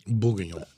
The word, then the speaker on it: Bourguignon: Bourguignon.